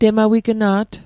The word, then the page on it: temawigna't: Mi'gmaq